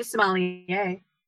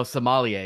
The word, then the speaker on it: sommelier: Oh, sommelier. Yeah, there's a good word. Mm-hmm. Yeah, rose sommelier. It, but there's wine involved. – I know there's an R in there, hmm. and you don't pronounce it.